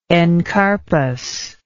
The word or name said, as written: encarpus